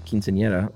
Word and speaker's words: quinceañera: And your pronunciation of quinceañera was great. – Nice. – As the E-R-A is a soft R sound.